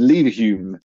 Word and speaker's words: Leverhulme: I've always said Leverhulme, which is like Hume, like the philosopher, right?